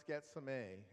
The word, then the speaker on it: Gethsemane: The word Gethsemane means olive press and would be pronounced Getsemane.